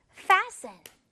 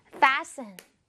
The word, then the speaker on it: fasten: 여기에 t는요, 발음해주지 않습니다. t는 발음하지 마세요. Okay? fasten. fasten.